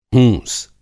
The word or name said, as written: हूँस